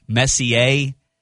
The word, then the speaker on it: Messier: and that's Messier. And I think I'm saying that right. M-E-S-S-I-E-R. Matt, you're the brains of the operation. Does that sound right? Is the R silent? Messier? Okay.